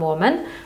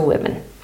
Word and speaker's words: women: I w wymowie jest lekka różnica, czyli mamy jedną kobietę, one woman, ale mamy dwie kobiety, two women.